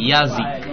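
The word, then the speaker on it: Yazik (язык): E poi la K sarebbe la C, quindi Yazik. Yazik sarebbe.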